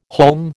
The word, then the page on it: haulm: American English